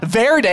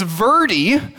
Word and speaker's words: Verde: It's not Verde, it's Verde, which a wise person told me rhymes with birdie.